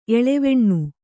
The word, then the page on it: ಎಲೆವನಿ: Kannada